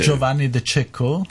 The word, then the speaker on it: Giovanni De Cecco: Giovanni De Cecco.